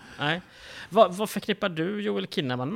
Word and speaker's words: Kinnaman: Man tror kanske att det uttalas Joel Kinnaman, men egentligen uttalas det Joel Kinnaman med hårt K.